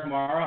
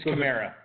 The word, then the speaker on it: Kamara: Alvin Kamara, I always pronounce it wrong. I don't really care. – It's Kamara.